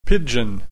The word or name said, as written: pidgin